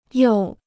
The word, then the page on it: 又: yòu